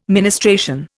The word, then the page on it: ministration: Indian